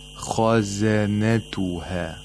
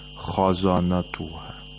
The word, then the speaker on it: خزنتها: And all the other letters in this word are letters of استفال, so you have to pronounce them with ترقيق. خزنتها. We don't say خزانتها.